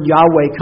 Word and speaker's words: Yahweh: L-O-R-D, all capped. That is the Hebrew tetragrammaton Y-H-V-H or Y-H-W-H that people would call pronounced Yahweh or Jehovah today.